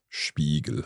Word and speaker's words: Spiegel: Now, interestingly, Spiegel, you might be thinking Spiegel, that's that sh sound at the beginning, but this time it's not an SCH. – Yes, there is an exception for when a word starts with SP or ST, then it's pronounced as sh. – Sh. Spiegel. Okay.